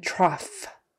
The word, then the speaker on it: trough: The next way it can be pronounced is off. So just like the last one, only this time the GH spelling is kind of turning into an F sound. So it's kind of like O-F-F or off, A-H-F, however you want to spell it, off. Because obviously O-U-G-H makes no sense. So cough, coughing, and trough.